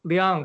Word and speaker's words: Liang: Liang, but that's also sort of a complicated question, actually, because there's like an American pronunciation, you know, and then there's like the actual Chinese pronunciation, and then that gets mixed up. But I've been saying Liang.